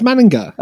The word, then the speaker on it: Manninger: I always thought it was Alex Manninger, but I had to rework on pronouncing it. It's Manninger.